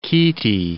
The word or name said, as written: chaetae